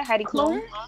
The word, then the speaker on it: Heidi Klum: Am I saying her name right? Heidi Klum. Yes.